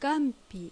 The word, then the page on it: Gampi: Japanese